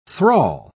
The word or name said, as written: thrall